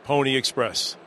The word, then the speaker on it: Pwnie Express: But unless you know us from our history of making cool devices, you might not know it's spelled P-W-N-I-E. E-X-P-R-E-S-S, Pony Express, and that's where you go.